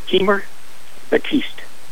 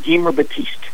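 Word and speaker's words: Gemar Batiste: Could you pronounce the name of the main character in that book? – His name is Guimer Batiste. – Guimer? – G-E-M-A-R. Guimer Batiste.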